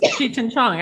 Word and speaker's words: Cheech and Chong: Cheech and Chong. – Yes, of course. Cheech and Chong.